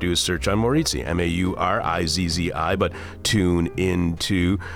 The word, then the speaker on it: Maurizi: Maurizi. – Maurizi, yes.